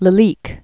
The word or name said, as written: Lalique, Rene Jules